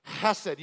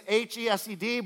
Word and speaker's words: hesed: And so the Old Testament was written in Hebrew, and the word for mercy here is the Hebrew word chesed hesed. You have to, it's an H E S E D, but you have to pronounce it like it's K H, like you're hawking up a loogie.